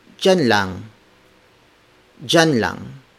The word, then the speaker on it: diyan: jan is spelled as D I Y A N. So the D I Y combination is often pronounced in Filipino like the letter J, similar to the letter J.